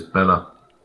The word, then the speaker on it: Bela: Any other thoughts on Bela? Bela, however you say his name? – It's Bela.